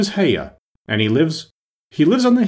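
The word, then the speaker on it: Haigha: His name is Haya." – He pronounced it so as to rhyme with mayor.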